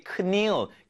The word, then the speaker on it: kneel: 이게 kneel, knelt 아닙니다. N 앞에 있는 K는 묵음이죠. Silent sound. 즉, 우리가 스펠링 철자는 적지만 실제 발음은 하지 않는다. 이게 silent sound. 우리말로는 묵음이 되겠습니다.